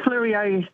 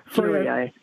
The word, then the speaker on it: Fleurieu: Is hosting one in South Australia, which is called the Heritage Floriu Festival. How am I pronouncing this? – The Heritage Fleurieu Post Festival. Fleurieu.